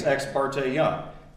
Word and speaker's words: Ex parte: One is how you pronounce Ex Parte Young. I think it's Ex Parte Young. How many of you think it's Ex Parte? Ex Parte? A surprising number of Supreme Court justices say Ex Parte.